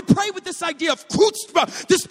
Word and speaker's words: chutzpah: It's kind of spelled like chutzpah but, but it's, it's chutzpah.